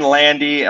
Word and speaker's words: Landi: And I'm Eric Landy. You can use my first name, Eric, E-R-I-K. That's spelled the right way. And Landy, L-A-N-D-I, first, last name.